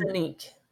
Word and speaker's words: Sinek: So you have a book here called The Infinite Game, and this is by Simon Sinek. Is that how you say his name? – Sinek is his name.